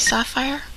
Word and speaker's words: Sapphire: My name is Sapphire. – S-A-P-P-H-I-R-E? – Yes, ma'am.